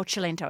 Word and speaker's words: Cilento: So we might think Diane Cilento. That's how you spell it. – Say it. Oh, Cilento, as you say.